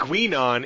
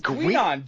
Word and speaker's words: Guinan: And it wasn't Gwinon. It was some weird pronunciation. – No, I'm reading it right Gwildon? Now. No, it was Gwinon. G-U-I-N-A-N.